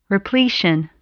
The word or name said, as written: repletion